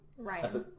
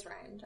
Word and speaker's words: Rian: Ryan. – Is it Ryan? – It's Rian Johnson. Oh, okay. It's spelled R-I-O-N, it's not I thought it was E-I-N, sorry.